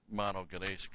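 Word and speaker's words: Monogenes (μονογενής): And that's where that word monogenes comes in. Mono only. Well, stick with English. Monogenes. That's a long A.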